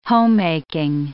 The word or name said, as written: homemaking